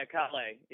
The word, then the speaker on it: Callais: Yeah, Callais.